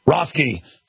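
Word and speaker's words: Roethke: Rothke, Theodore Rothke.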